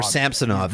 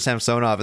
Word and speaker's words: Samsonov: They changed how they pronounced his name over the last year. That last year it was Samsonov, and this year it's Samsonov. I feel. Man, when he signed, it was definitely Samsonov, right? – That's what I thought.